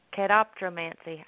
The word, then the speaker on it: catoptromancy: Um, and apparently, mirror scrying is also called catap- cataptromancy. Catoptromancy. I think I'm saying that correctly.